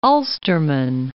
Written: Ulsterman

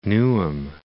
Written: Newham

